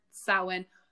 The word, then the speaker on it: Samhain: and it is spelled S-A-M-H-A-I-N. So if you've seen that, a lot of people pronounce it Samhain because they've never heard, it's like an Irish Gaelic word, Samhain. However, it's a very ancient word. We don't actually know that it's supposed, we think it's pronounced Samhain, but we don't actually know that.